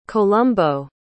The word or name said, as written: columbo